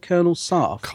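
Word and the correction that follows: Colony Sarff: Colony Sarf.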